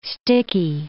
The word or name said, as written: sticky